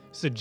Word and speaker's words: Sagaris: I'm also not convinced that I've been saying that wrong my entire life. – Cigarettes. – No, yeah. Sagaris. – It does sound like that, right? Cigarettes? – Yeah, it does.